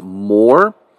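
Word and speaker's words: more (מור): It's spelled M-Y-R-R-H. The Hebrew pronunciation is more.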